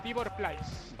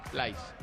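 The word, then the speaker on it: Pleiß: Yo diría que es Tibor Plais. – Vale, Plais.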